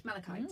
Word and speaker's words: malachite: So this is it malachite? – Malachite, yeah.